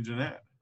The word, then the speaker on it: Gennett: You like Jeanette or Gennett? – I mean, there's only one right way, so I like the right way. – Is it definitely Jeanette? – Yes, it's definitely Jeanette. – I wanted to call him Gennett for some reason.